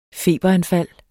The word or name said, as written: feberanfald